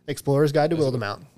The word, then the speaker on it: Wildemount: That is Wildemount. Y'all can suck a dick. – Go at Matt Mercer. – The E is silent. – It's Wildemount.